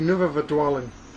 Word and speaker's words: Nuwe Verdwaling: Is that the correct pronunciation? – Yes. – Die Nuwe Verdwaling.